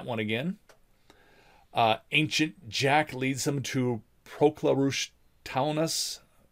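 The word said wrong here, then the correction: Taonas: Teonas. – Proclarush Teonas.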